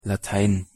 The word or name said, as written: Latein